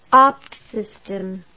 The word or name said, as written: ABT system